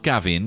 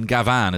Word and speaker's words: Gavan: My cousin Gavan, not Gavin, Gavan as it's spelled here.